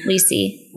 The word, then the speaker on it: Lacey: So the original Becky in seasons 1 through 5 was played by, is it pronounced Lessie? Lacey? – Lacey? – Lacey? – Lacey.